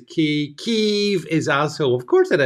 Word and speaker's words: Kyiv: That's because we must never pronounce it as Kiev, as in chicken. I mean, that's one thing I've learned in the past 2 years. Always call it Kyiv. And the longer you make the E, that shows how much more virtuous you are, you see.